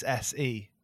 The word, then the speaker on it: Hesse: I think that's the way you pronounce it. Hesse.